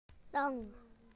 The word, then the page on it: long: British English